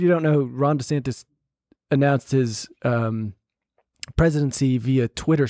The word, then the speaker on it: DeSantis: By the way, it's DeSantis, apparently. That's how he pronounced it himself, his own name.